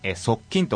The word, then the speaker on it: 速筋: で速筋は速い、スピードが速いの速いに筋肉と書いて、速筋と読ませていただいております。